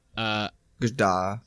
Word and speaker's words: Gadot: Gal Gadot, or Godot, I don't know how you say her name. – Gadot.